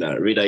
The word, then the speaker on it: Rida: So, you know, and Rida is kind of like, you know, like the pronunciation is Rida, but a lot of people confuse it for like Rida somehow.